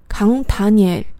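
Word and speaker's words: 강다니엘: 那么他的名字姜丹尼尔完全是音译，康塔尼尔，康塔尼尔。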